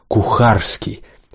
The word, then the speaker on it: Кухарский: чье имя Петька и Избышек произносили как Кухальский или Кухарский, всегда именно так, нажимая на «харский».